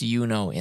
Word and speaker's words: Juno: And then you've got Yuno, which this one's the only like direct correlation for me is she's named after Juno, pronounced Yuno in Latin, the goddess of motherhood and femininity.